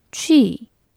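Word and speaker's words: 취 (去): 가다는 취, 봄은 춘.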